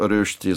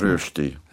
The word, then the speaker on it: Rösti: Rösti sein, ja. – Also, Sie können das mit den Rösti gut aussprechen. – Rösti. Rösti. Ja, Na, ich habe ja sehr viel davon gegessen.